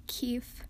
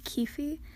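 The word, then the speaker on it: Keefe: So, I pronounce Keef, not Keefy, but I still love how you support Keef, so keep making episodes.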